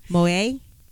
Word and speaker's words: Moyet: I'm trying to get it right. Francois Moet. Moet? Moet. M-O-Y-E-T. Francois, I think, Moet.